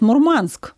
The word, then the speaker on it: Мурманск: Ну, кстати, вот с ударениями это такая история неоднозначная, потому что мы говорим Мурманск, да, а моряки говорят Мурманск. То есть у них вот это такая культура. Мы говорим компас, они говорят компас.